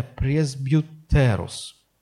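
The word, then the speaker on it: Пресбьютерус (πρεσβύτερος): Нет, с ударением на «э». Пресбьютерус. Пресбьютерус. От него происходит русское слово «пресвитер».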